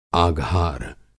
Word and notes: आघार: Hindi

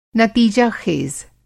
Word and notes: नतीजाख़ेज़: Hindi

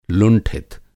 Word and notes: लुंठित: Hindi

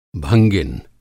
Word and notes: भंगिन: Hindi

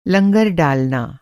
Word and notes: लंगर: لنگر; Hindi

